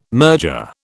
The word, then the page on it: merger: British English